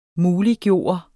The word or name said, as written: MULIGGJORT